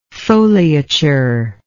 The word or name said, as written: foliature